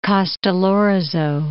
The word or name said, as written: Castellorizo